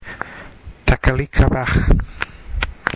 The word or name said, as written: Takalik Abaj